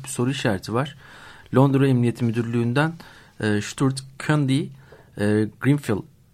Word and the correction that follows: Grenfell: doğru telaffuz ediyorum değil mi? – Grenfell. – Grenfell Tower yangında en az 58 kişinin kayıp olduğunu ve bu kişilerin yaşamını yitirdiğini de varsayıldığını söylemiş.